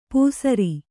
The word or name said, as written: ಪೂಸರಿ